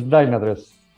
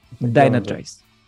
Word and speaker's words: Dynatrace: Es Dynatrace. – Dynatrace.